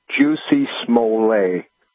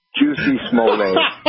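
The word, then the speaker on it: Jussie Smollett: Well, the number one is it's pronounced Juicy Smollett. It's a French name. I just, Dave Chappelle said that's how it's to be pronounced, is Juicy Smollett.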